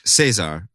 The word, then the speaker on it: Cesar: His name is, let's just say his name is Caesar, spelled S E S-A-R with the emphasis on the E there. Cesar, if you will.